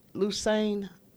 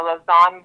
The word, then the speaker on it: Lausanne: Now, is this pronounced Lusane? Lusane? L-A-U-S-A-N-N-E? Is it Lusane? – Oh, Lausanne. – Lausanne. Okay, Lausanne.